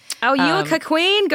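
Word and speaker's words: cuckquean: Cuckween, I believe, is how it's pronounced.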